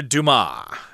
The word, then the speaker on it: Dumas: Now, The Count of Monte Cristo sprang from the fertile imagination of legendary French author Alexandre Dumas. Remember, the S there is not pronounced in French, at least in this particular instance.